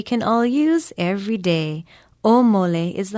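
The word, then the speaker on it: Omole (ʻōmole): Omole is spelled with an okina, O-M-O-L-E, and there is a makron or kahako over the first vowel. Pronounce it omole.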